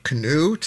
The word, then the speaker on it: Kinew: Rob Kinew, to say his name accurately, Premier of Manitoba, showing a little solidarity with Ontario, the province to its east.